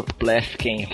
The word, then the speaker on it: Pleffken: Como que é seu sobrenome, Bruno? Eu não consigo pronunciar ele. – Olha, geralmente é assim. Alguém vai me chamar, demorou para ler, sou eu. É Bruno Plefken Parabéns.